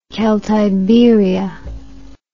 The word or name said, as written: celtiberia